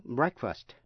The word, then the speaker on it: breakfast: Thí dụ như âm EA trong chữ break, break phát âm là A, nhưng trong chữ breakfast, breakfast là bữa ăn sáng thì phát âm là E, break.